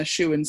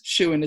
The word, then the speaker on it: Shewan: Hannah Shewan Stevens. Shewan is spelled S-H-E-W-A-N, I know it's a weird middle name.